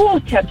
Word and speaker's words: water: You can't say the letter T, you have to say the letter W. – Okay. Give me a bottle of water, please. – There you go. You just nailed it. You got the BBC pronunciation.